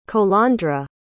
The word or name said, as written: colandra